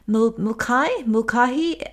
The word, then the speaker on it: Mulcahy: It's the memoir of a gossip columnist called Susan, I think you pronounce the name, Mulcahy, M-U-L-C-A-H-Y, because my lips are sealed.